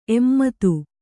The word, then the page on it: ಎಮ್ಮದು: kannada